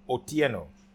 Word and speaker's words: Otieno: Siaji Otieno. O-T-I-E-N-O.